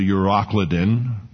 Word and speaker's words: Euroclydon: But not long after, there arose against it, the ship, a tempestuous wind called a Euroclydon. Now, depending, I have the pronunciation markers here. Some call it a Euroclydon.